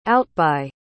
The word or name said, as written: outbye